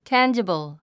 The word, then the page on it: tangible: tǽndʒəbl; タァンジボゥ